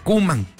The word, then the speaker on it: Koeman: O sea, realmente se pronuncia Koeman, ¿verdad? Erwin Koeman y Ronald Koeman, que estaban en Países Bajos, allá en Italia 90.